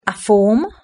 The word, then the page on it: a form: British English